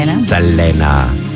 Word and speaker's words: Selena: Selena.